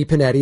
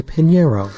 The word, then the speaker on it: Pinheiro: And it's not Eddie Pinetti. It's Eddie Pinheiro. Get his name right.